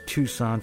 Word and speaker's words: Tucson: That's actually Tucson for you people pronouncing phonetically.